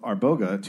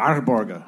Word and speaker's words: Arboga: Then in late 1571, John granted the small city of Arboga to Cecilia. Did I butcher? Arboga? Arboga. Yeah, you gotta roll the R's. It's like a small Arboga.